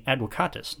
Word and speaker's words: advocatus: Now you may notice I'm saying advocatus, not advocatus. I'm using the classical pronunciation. The ancient Romans pronounced V like W, so I will too.